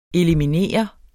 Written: ELIMINERES

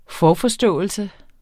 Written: forforståelse

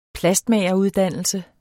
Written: plastmageruddannelse

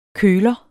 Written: køler

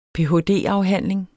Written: ph.d.

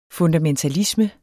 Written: FUNDAMENTALISME

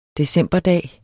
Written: decemberdag

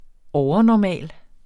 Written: overnormal

